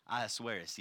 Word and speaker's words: Ahasuerus: And I'm going to pause for just a second and look at my friend Ahasuerus, right? Ahasuerus? Ahasuerus, yes.